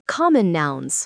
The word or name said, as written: common nouns